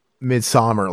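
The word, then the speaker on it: Midsommar: Midsummer, like summer. – I think it's pronounced summer, but it's like how they spell it is Swedes.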